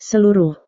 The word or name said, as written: seluruh